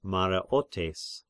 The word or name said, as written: Mareotes